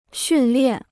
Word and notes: 训练: xùn liàn

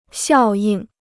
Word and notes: 效应: xiào yìng

